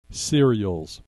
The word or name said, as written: cereals